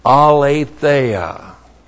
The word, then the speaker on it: aletheia: Aletheia. A-L long E-T-H-E A-L-E-T-H-E-I-A.